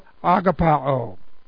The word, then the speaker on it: agapao: The present active participle of agapao. And agapao is the verb form. I started to get a long O. Agapao. And this is the verb of agape.